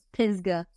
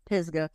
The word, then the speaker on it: Pisgah: Is it Pisgah? Pisgah? – It's Pisgah. Like Pisgah. – With a Z, Pisgah. And you can always tell when somebody's from up north because they call it Pisgah and it drives me up the wall.